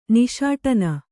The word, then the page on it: ನಿಷ್ಠಾನ: niṣṭhāna